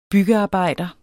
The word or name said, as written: byggearbejder